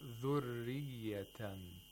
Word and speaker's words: ذُرِّيَّةً: It has to be pronounced with تَرْقِيق, just like how Sheikh Minshawi read. Actually pronounce this ra. He said dhurriyatan, okay, without takrir, okay? He didn't say dhurriyatan.